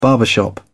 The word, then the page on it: barbershop: British English